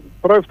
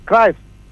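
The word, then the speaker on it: Cruijff: Λοιπόν, όπως και ο Κρόιφ το λέγαμε εμείς, Κράιφ το λέγανε.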